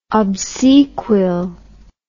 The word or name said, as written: obsequial